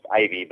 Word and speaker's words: IVI: The company's name is actually pronounced Ivy, but the domain is IVI.TV.